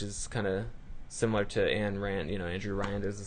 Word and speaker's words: Ayn Rand: Ayn Rand.